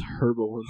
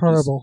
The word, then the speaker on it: herbal: I love that Evan says herbal instead of herbal. – Just herbal. No silent H. No. Evan wants that H to be known.